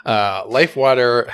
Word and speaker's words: LIFEWTR: Number 4, I think I will take Life Water. I think that's how it's pronounced, right? – Yes, for the listeners who are unaware, Life Water has no vowels in water. So it's LIFEWTR.